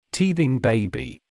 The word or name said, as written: teething baby